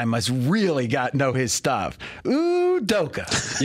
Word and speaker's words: Udoka: My favorite new coach, at least I like to say his name, for the first time ever, Udoka. – There you go. – Does that work? – Yeah.